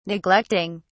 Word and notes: neglecting: British English

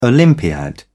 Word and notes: olympiad: British English

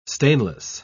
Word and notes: stainless: British English

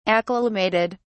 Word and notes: acclimated: British English

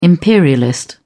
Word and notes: imperialist: British English

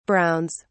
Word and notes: browns: British English

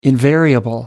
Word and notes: invariable: American English

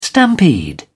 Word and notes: stampede: British English

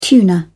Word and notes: tuna: British English

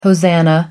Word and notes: hosanna: American English

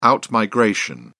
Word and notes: out-migration: British English

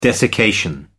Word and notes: desiccation: American English

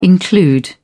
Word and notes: include: British English

